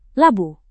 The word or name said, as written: labu